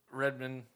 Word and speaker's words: Redmayne: Redmayne.